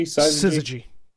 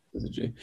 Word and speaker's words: syzygy: Is the actual pronunciation? Is it Syzygy? – Syzygy. – Syzygy.